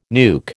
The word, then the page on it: nuke: American English